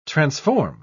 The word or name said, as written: transform